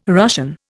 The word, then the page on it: Russian: Indian